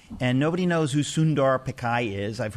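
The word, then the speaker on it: Pichai: Pickai. – Pickai? – Yeah.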